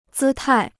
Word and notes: 姿态: zī tài

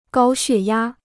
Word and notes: 高血压: gāo xuè yā

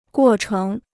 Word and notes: 过程: guò chéng

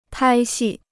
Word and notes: 拍戏: pāi xì

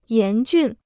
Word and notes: 严峻: yán jùn

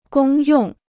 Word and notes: 公用: gōng yòng

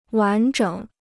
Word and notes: 完整: wán zhěng

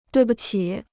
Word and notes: 对不起: duì bu qǐ

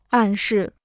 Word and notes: 暗示: àn shì

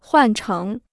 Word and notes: 换成: huàn chéng

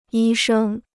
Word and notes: 医生: yī shēng

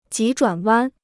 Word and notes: 急转弯: jí zhuǎn wān

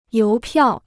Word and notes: 邮票: yóu piào

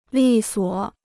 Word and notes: 利索: lì suo

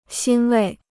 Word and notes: 欣慰: xīn wèi

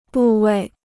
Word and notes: 部位: bù wèi

